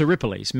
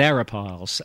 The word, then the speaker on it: Arapiles: Well, it's Aripiles, Mount Aripiles. – That's right. – but silly Gary said Mount Arapiles.